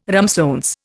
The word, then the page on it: ramsons: Indian